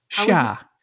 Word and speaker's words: Xia: Western Xia? – Xia.